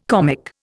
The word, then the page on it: comic: Indian